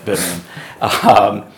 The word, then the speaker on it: Birmingham: Or Birmingham, however you pronounce it. – Birmingham. – Birmingham.